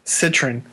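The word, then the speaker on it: Cytryn: Pretend the Y's are short I's, so okay, it's just Citrin. – Say it for me. I want an example. – Citrin.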